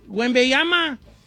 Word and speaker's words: Wembanyama: Wembanyama. – Wembanyama.